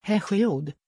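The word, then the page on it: hesiod: Swedish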